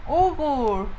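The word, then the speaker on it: Uğur: Y los novios Uger y Oslim. – Ugur. Ugur. – Ugur como Hugo. – No, Ugur. Ugur.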